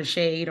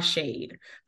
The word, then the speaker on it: Shadé: You can find me across the internet at Hey Dr. Shade or Hey Dr. Shade. My name is spelled like Shade.